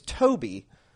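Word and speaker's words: Tobi (טוב): It's Tobi. It's easy to say, but it's spelled T-O-W-B, which is a bit tricky to look at and read instantly.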